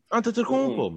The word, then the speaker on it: Antetokounmpo: Antetokounmpo, man.